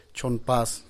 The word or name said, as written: txonnpas